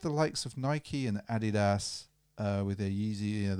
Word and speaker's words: Adidas: I think also if you're German, you say Adidas, so that is the correct way to say it.